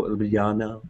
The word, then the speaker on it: Ljubljana: The capital, Ljubljana, is cool as well.